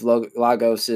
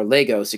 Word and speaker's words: Lagos: And it's basically, as much of Lagos is, or Lagos, excuse me, is how you pronounce it.